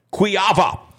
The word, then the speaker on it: Kujawa: His name is spelled Tim Kujawa, but it's Tim Kuyava.